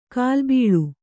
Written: ಕೋಲಬಳಿ

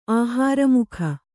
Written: ಆಹಾರಮುಖ

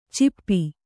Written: ಚಿಪ್ಪಿ

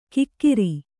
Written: ಕಿಕ್ಕಿರಿ

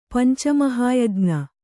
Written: ಪಂಚಮಹಾಯಜ್ಞ

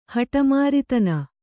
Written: ಹಟಮಾರಿತನ

